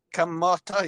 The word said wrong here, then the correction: kamatis: Kamata. Close. Kamatis. – Mata. Kamatis. Oh, kamatis. Yeah. – Kamatis in Tagalog.